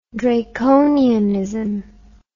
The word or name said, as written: draconianism